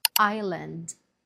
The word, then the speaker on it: island: Island. Island. The S is silent.